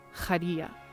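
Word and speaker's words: Khairia: So in a name, for example, like the actress that plays Bayan, if you spell her name out, you Romanize it and spell it out, it's K-H-A-I-R-I-A, right? So it's pronounced Khadija, Khadija Abu Lavan, right?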